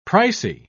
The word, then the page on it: pricy: British English